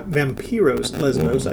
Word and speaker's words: Vampiros Lesbos: He chimes in with Vampires Lesbos, Vampiros Lesbos, I should pronounce it correctly.